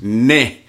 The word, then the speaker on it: neh: You know, a lot of people that might listen to this now, it's pronounced neh. N-E-H. Come on, neh.